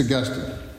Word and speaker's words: Augustine: I mispronounced his name for the first 50 years of my life. It was only when I became acquainted with more spiritually robust and theologically informed people that I realized I have not been saying his name right. It's Augustine.